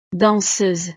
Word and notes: danseuse: frances

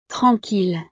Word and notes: tranquille: frances

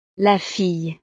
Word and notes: la fille: French

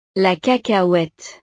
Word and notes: la cacahuete: frances

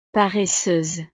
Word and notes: paresseuse: frances

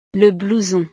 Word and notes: le blouson: frances

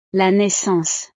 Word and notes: la naissance: frances

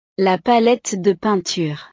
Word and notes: la palette de peinture: frances

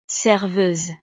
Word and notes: serveuse: frances

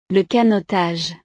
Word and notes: le canotage: frances

